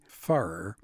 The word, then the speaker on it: Pfarrer: Harry Farrer, and that's spelled P-F-A-R-R-E-R, played by George Clooney.